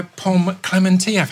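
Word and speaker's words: Pom Klementieff: Right, I'm joined here today by Pom Clementieff.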